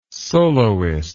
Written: soloist

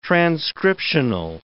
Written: transcriptional

